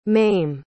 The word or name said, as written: mayme